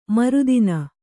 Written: ಮರುದಿನ

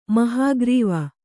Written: ಮಹಾಖರ್ವ

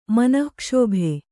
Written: ಮನಃಕ್ಷೋಭೆ

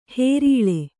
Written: ಹೇರೀಳೆ

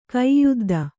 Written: ಕೈಯುದ್ದ

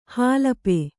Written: ಹಲಪಿ

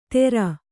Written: ಥೇರ

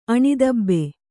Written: ಆನೆತೂಬು

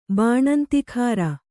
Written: ಬಾಣಂತಿಖಾರ

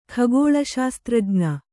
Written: ಖಗೋಳಶಾಸ್ತ್ರಜ್ಞ

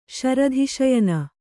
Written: ಶರಧಿಶಯನ

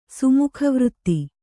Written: ಸುಮುಖವೃತ್ತಿ

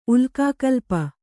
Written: ಉಲ್ಕಾಕಲ್ಪ

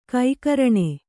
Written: ಗೋಕರ್ಣ

